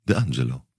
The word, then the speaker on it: d'Angelo: Wie spricht man Italienisch aus dann? – De Angelo. – De Angelo. – So muss man das sagen.